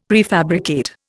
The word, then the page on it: prefabricate: Indian